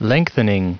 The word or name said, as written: lengthening